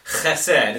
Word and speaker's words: chesed: The Hebrew of it is chesed. It's one of those words that sounds like you've got something in the back of your throat if you're going to say it right.